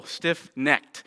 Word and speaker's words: stiff-necked: I have to be careful because I want to do that into a two-syllable, right? Stiff-necked. And right, some of you from the South, you're like, that means something different. So it's just one syllable, stiff-necked. As your ancestors were, right, don't do that.